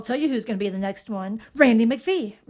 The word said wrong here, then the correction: Randy McKee: Randy McKee. I said it wrong.